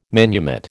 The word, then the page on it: manumit: American English